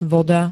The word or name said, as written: voda